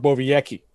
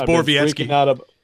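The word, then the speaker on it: Borowiecki: See, if you had any guts, you would have gone with Mark Bovejecki, okay? That's and by the way, did I botch that pronunciation? – Yes, you did. It's Bovejecki.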